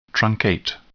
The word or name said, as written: truncate